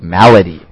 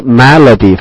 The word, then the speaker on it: malady: Next we have the word melody, melody, M-A-L-A-D-Y, melody. – 第三个单词 melody, 翻译成中文是疾病。注意它的发音它不是 melody, 是 melody, right? – Yeah, right. So a lot different than the word melody.